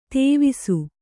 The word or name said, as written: ತಿವಿಸು